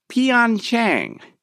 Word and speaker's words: Pyeongchang: It's the Winter Olympics, and every native-speaking Korean, those that have learned the language, are grimacing whenever an NBC television announcer mispronounces the host city's name, Pyeongchang. It's what it's called, not Pyeongchang.